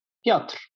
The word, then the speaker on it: Piotr: I guess I'll be involved for a while, which makes Mom happy every time I say I talk to, I don't know how I would pronounce it. It's P-I-O-T-R. – Piotr.